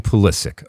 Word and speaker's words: Pulisic: I'm calling him Christian Pulisic, okay? K at the end, an accent on the second syllable.